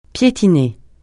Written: piétiner

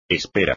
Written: espera

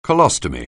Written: colostomy

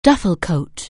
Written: duffel coat

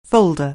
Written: folder

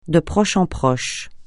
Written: de proche en proche

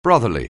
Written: brotherly

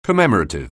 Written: commemorative

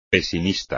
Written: pesimista

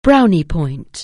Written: brownie point

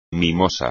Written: mimoso

